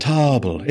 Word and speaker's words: Toble: Tarble? – Yes. – Tarble or Tarble? – Tarble. It's spelled like table, which isn't a thing I was just looking at. It's Tarble. – Tarble. – T-O-B-L-E.